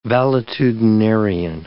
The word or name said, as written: valetudinarian